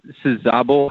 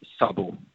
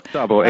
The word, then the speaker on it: Szabo: It's Daniel Szabo, how English-speaking folks typically pronounce it. But if you really want to go by the Hungarian pronunciation, it's Szabo. – Szabo. Okay, so Daniel Szabo. – Szabo, exactly.